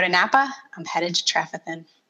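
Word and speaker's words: Trefethen: It's called Trefethin. I said it correctly earlier. It's Trefethin.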